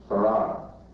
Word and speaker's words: Fard: And I believe that the teacher of my father, who you find in the Encyclopedia Britannica under the name Fard, F-A-R-D, pronounced Farrar.